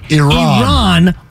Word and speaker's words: Iran: Pronounce it right. – Iran 1.